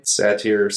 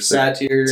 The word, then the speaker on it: Satyr: So his name is Satyr, right? Is that how you would say his name? – Satyr. – Satyr. – Something like that. – Satyr, yeah. he's